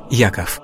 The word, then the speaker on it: Яков: В современном русском языке имя Иаков обычно произносится как Яков.